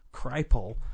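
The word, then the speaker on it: Kripal: he actually said, you, all caps, have got to get Jeff Cripple, or according to his website, as his immediate family pronounces his last name, Cripple, to be a guest on our show.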